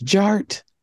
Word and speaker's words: DJ Art: DJ Art, with two T's for a double dose of that tink tink. The D is silent, so it's just Jart.